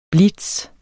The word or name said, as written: BLITZEN